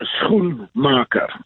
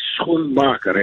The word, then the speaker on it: Schoenmaker: Yeah, sometimes we give up, but it is Schoenmaker. So SG, you know, like Schoen. And not SK like in South Africa or Shoe. No, it's Schoenmaker. It's Dutch. – There we go. René Schoenmaker, thank you very, very much for talking to us.